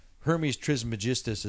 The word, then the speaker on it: Hermes Trismegistus: Hermes Trismegistus.